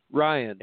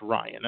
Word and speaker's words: Ryan: Is it Ryan Healy or Rion Hidley? – Ryan. – It's Ryan. Okay.